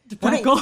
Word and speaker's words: De Pecol: De Pecol.